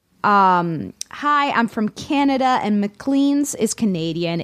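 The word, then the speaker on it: Maclean's: It's McLean's.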